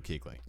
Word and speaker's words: Kuechly: Keep in mind, if you have LASIK surgery, do not go anywhere near Luke Kuechly.